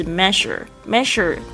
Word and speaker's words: measure: 这个 love without， without 就是不要，不要做什么事情呢，就是 measure 这个要注意一下发音了，这个 ea 在这边是发的这个声音